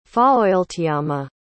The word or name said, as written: faoiltiama